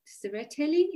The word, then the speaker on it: Tsereteli: He's an associate member of the Department of Yazidi Studies at the Georgi Tsereteli Institute, am I pronouncing it correctly? – Yeah.